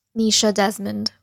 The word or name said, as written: Neesha Desmond